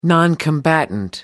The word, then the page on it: noncombatant: British English